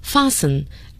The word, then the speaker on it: fasten: 在这一段中，大家尤其要注意到一个单词 tied，强调把某人捆起来 tie，但如果我们要说系，有可能用 tie，也有可能用 fasten，要注意它的发音 fasten， F-A-S-T-E-N。